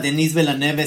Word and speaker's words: Denis Villeneuve: No sé qué piensas tú con este director que a mí sí me gusta, Denis Villeneuve, este que está haciendo Dunas.